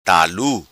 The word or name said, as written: Talu